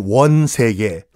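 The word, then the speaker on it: 원세개: 위안 스카이야가 아니라 원래 이름이 위안 스카이고 우리식으로 발음하면 원 세계.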